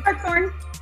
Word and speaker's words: Hartshorn: Hartshorn. – Hartshorn. – Wait. Yeah, like if a heart had a horn.